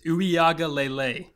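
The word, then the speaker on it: Uiagalelei: Hey, I'm saying it right. It's Uiaga Lele.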